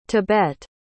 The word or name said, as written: tebet